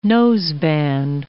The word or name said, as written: noseband